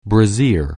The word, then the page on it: brassiere: anglais